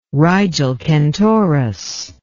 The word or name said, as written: Rigil Kentaurus